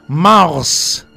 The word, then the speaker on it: mars: Se pronuncia Mars como la Marte. – Como Marte.